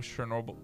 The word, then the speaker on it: Chernobyl: You know Chernobyl. – Chernobyl, yeah. – I said that wrong. – Yeah, no, you got it.